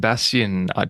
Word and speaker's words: Bastian: Adam's missing today, but we have the amazing Orian Bastian.